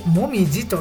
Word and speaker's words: 紅葉: この辺の朝の名前が紅葉と書いてもみじと読むようなんです。